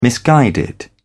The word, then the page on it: misguided: British English